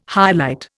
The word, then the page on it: highlight: Indian